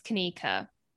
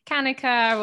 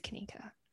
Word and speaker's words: Kanika: I remember so vividly, like it just rolling off the tongue, me being like, it's Kanika, after it being like pronounced Kanika, or that like hesitation. You could see their face screw up, like as they're trying to figure out how to pronounce it. I'd just be ready every time, like, it's Kanika.